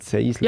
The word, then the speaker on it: Sensler: Seisler. – Seisler? – Seisler. – Wie schreibt man das? – Sensler. – Wie schreibt man das? – Sensler mit N. S-E-N-S-L-E-R. – Und man sagt Seisler? – Ja, anscheinend.